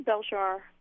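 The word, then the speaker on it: Belzhar: So I'm going to give you a chance to introduce, am I saying it right, Beljar? I know it's... – I say Beljar. Okay. A little stress on the first syllable more, but it's fine.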